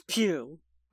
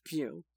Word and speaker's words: Pugh: Okay, it is Pugh. I just looked it up. I'm real happy for you, and I'm going to let you finish, but it is pronounced Pew.